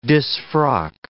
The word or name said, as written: disfrock